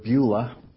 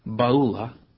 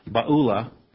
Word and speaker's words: Beulah: And your land, and now we usually pronounce this word Beulah, but it's Baulah. It sounds like one of the institutions we have out there called Biola, but I'm sure that's where it comes from. Baulah.